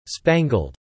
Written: spangled

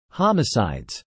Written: homicides

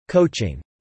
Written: coaching

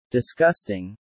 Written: disgusting

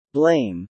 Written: blame

